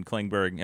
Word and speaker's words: Klingberg: On Giordano and Klingberg. Am I saying this right? Am I doing this right? – Yes, you are.